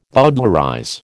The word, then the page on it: bowdlerize: American English